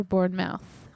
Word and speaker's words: Bournemouth: Bournemouth or Bournemouth? – It's spelled like mouth, but all the English people I listened to pronounced it muth, Bournemouth.